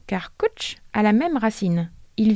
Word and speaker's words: cwtch: Il s'écrit C-W-T-C-H. Attends, mais euh, on le prononce comment ça Ça se dit coach.